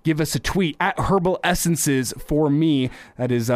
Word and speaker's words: Herbal: It's herbal. You pronounce the H and you put the E in there. Herbal, herbal essences.